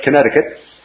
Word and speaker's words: Connecticut: It looks like Connecticut, right? And so there's another one of those instances where the pronunciation is a little strange, but Connecticut.